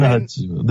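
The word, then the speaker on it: Djimon Hounsou: So Jaimin Honsu. The D's silent, like Django. Honsu.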